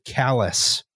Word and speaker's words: Calais: No, we did the Main Street crossing from St. Stephen to Calais, although I believe they actually pronounce it callous.